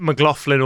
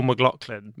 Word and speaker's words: McLoughlin: It is my name, but I think I accept McLoughlin or McLoughlin because I have family all say McLoughlin, but I genuinely, on even the most basic research, it implies that my family pronounced their own surname wrong because it's an extraordinarily Celtic name, and it's been anglicised.